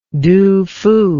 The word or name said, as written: Tu Fu